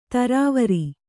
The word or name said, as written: ಥರಾವರಿ